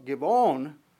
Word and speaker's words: Gibeon: When you consider the hill of Gibeon, it actually probably looks like more with a B, but probably pronounced with a V.